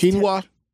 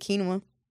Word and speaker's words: quinoa: So they had a trifecta chili with quinoa. Okay, so it was quinoa. – How do I say it? Quinoa. – Yeah. – Quinoa.